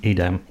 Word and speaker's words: Edam: Is it Edam? E-D-A-M? – Edam. – Edam. From the Netherlands. – That's an interesting cheese because that's made backwards. – What do you mean it's made backwards? – E-D-A-M.